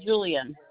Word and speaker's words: Julian: Sharon Julian. – Could you spell that last name for me? – J-U-L-I-A-N. – J-U-L-L-I-A-N? – No, one L.